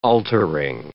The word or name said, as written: altering